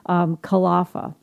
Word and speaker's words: Kalafa: the accent's on the first syllable. So Kalafa from Two Angry Moms.